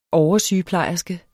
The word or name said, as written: oversygeplejerske